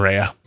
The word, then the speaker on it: Rea: Three letters and two syllables. It's confusing to a lot of people. – Because it can easily be just read as Ray.